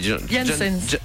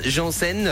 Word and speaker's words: Jensen: Mais comme il y en a qui disent Janssen, alors parce qu'il le prononce mal, c'est Jensen.